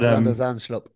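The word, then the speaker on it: Van de Zandschulp: Van der Zandstrup, I think is how to pronounce it. – But, Van der Zandstrup, yep.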